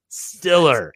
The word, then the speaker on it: Steeler: Feels like I'm at a Steeler bar. I'm sorry, a Stiller bar. Stiller. – I say it right. – That's what it's supposed to be.